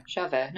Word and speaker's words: Javert: But Where the Cash At and Javert, I fucking mispronounced it again, didn't I? – Javert. No, you got it.